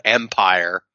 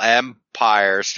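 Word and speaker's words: Empyre: And then this cut to what apparently the story is called Empire, and it's spelled P-Y-R-E, by the way. – Yeah. – You know, like a pyre.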